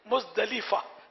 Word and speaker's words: مزدلفہ: اردو میں مزدلفہ بولتے, ویسے عربی اس کی وہ تلفظ ہے مزدلفہ، لام کے نیچے زیر ہے، لیکن مزدلفہ چل جائے گا، اردو میں بولتے ہیں.